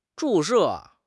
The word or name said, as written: 注射